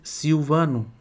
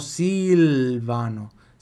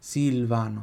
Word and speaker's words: Silvano: Olha meu nome em português, Silvano. O L tem som de U. Em italiano, Silvano. Silvano, Silvano. Soldatini, soldato.